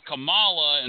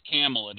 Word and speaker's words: Kamala: And I've also heard it pronounced Kamala, and that's how the wrestler used to pronounce it, but I think it's Kamala.